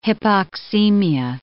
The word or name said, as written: hypoxemia